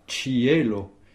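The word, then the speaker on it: ĉielo: Cielo, ça s'écrit C chapeau I E L O. Et où est l'accent tonique? – Sur le E. – Sur le E. – Voilà, sur le E qui se prononce E, effectivement. Cielo. Cielo. Cielo. Il y a Cielo. Trois syllabes au sens des espérantistes. Oh. Et c'est simplement la lettre E que l'on accentue.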